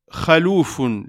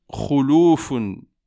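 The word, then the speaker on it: khuloufun (خلوف): Le terme doit être prononcé « khuloufun » avec une dhamma sur le « kha » et non une « fatha ».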